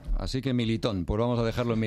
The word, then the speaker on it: Militão: Dicen los portugueses que es Militão.